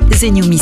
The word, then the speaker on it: TheNewMe: Zenyumi s'écrit THENEWME.